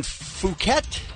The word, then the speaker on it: Phuket: American tourist was arrested at the airport in Phuket, Thailand. Is that how you say it? – Yes, that's how you should say it, yes.